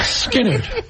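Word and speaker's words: Skynyrd: Well, Skinner, the Y substitutes for an I.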